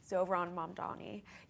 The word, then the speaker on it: Zohran Mamdani: Zohran Mamdani. He says in interviews that it has a soft H, and I've been thinking about that a lot.